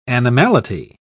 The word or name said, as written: animality